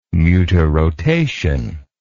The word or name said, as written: mutarotation